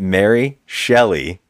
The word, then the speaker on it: Shelley: You keep saying Schelling, who's not yet Mary Shelley, but... – Okay, so what's even crazier is that Mary Shelley is in the courtroom.